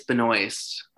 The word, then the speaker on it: Benoist: But I'm looking at all these students and how do you say Melissa Ben? – Oh, I have no idea. – I think it's Benoist. – Benoist? Okay. – And she went to our college. – Benoist.